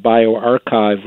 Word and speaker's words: bioRxiv: They've been putting everything up there on the internet through this interesting preprint server called BioArchive, which is spelled BioRxiv.